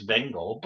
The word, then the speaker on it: Venger: How do they pronounce it? – They say Venger.